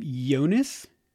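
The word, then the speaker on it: Joonas: I think you say his name Jonas. It spells with a J, but I think it's pronounced with a Y sound.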